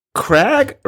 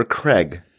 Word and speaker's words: Cragg: Is it Cragg or Craig? I never thought about it, but it sort of sounds like Crag, like the aggro Crag,